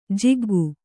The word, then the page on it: ಜಗ್ಗು: jaggu